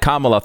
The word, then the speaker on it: Kamala: we actually decided to actually figure out how to pronounce it. We nailed it. We had it Kamala throughout the entire primary, then she went away.